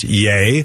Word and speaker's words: Ye: I'm sorry, I think I said Ye before, but it's pronounced Yay.